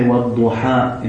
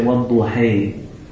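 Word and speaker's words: والضحى: And for example, the alif is pronounced differently. We say, وَالْضُحَى in Hafs. In other recitations, they say, وَالْضُحَيْ. It's called imala.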